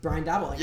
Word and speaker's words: Daboll: So it was Daboll. – Brian Daboll. – Yeah. – Okay, so that's how you pronounce Brian Daboll.